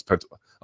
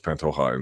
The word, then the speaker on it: Pantoja: Oh, it's Pantoja. A buddy of mine is Venezuelan, so it's Pantoja in Spanish.